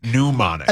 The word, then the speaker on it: pneumonic: Pneumonic. – Pneumonic. – As in pneumonia. – Yeah. Okay. All right. – That's just okay. Just silent P.